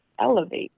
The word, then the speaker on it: LAV8: And it was like L-A-V-8. And how do you say it? Elevate. L-A-V-8.